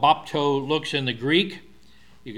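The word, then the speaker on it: bapto (βάπτω): But the little table with two legs, that's the P, then the T, and then the thing that looks like a W is the long O sound, bapto.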